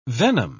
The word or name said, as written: venom